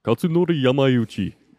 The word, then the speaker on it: Kazunori Yamauchi: How do you say it? – Where? Let me see it. – It's right here. Highlighted for you. – Kazunori Yamauchi. – Excellent. Thank you.